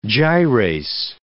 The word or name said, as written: gyrase